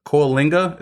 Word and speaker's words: Coalinga: And then Coalinga, is that how you pronounce it? – Yes.